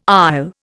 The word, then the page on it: isle: Indian